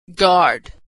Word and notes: guard: g

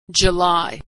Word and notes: July: dzh

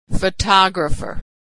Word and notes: photographer: female

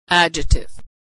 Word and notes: adjective: dzh

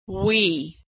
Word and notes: we: /w/

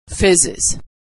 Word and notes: fizzes: Iz

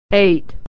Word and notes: eight: e-ey

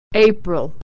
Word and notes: april: ey

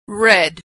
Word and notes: read: past

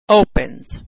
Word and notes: opens: ending z

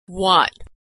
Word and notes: what: /w/

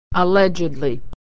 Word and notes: allegedly: ed

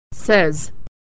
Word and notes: says: e

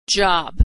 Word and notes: job: dzh

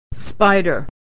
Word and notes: spider: /ay/; American English

